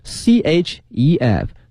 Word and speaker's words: chef: 但是今天我们学的是一个职业的厨师，叫 chef，chef c h e f c h e f 这个词啊，大家一定要小心了它是 c h 的两个字母，但是不要念成我们说另外一个音，它是这个诗音 chef，因为呢它是一个法语词，所以大家一定要小心了，这个发音叫 chef。